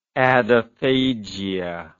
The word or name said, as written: adephagia